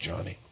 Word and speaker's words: Joni: Her first name is pronounced Johnny. It's spelled J-O-N-I, but it's pronounced Johnny.